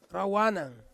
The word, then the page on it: rawanang: Ansus